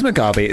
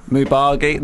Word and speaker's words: Mugabe: It's Mugabe. It's a weird word. It sounds like you're saying it wrong. Have I got the B and the G right? Mugabe. That's right, isn't it? Mubagi? No, it is Mugabe.